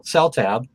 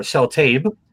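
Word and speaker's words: Seltaeb: There's 150 items that came out here in the United States from the company that Brian Epstein started that did the licensing called Celltab or Celltabe. There's 2 different pronunciations.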